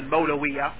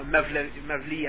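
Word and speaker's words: Mevlevi: Irrespective of what we're going to say now about Jalaluddin Rumi, the point is that those people who call themselves a Mawlawiya, or you know, a Mevli as they pronounce it in Turkish,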